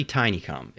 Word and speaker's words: Tinicum: This settlement made what would become it's literally Tinycum. It's Tinycum, so Tinycum. – Okay. But at least in Philadelphia, they'll pronounce it Tinycum.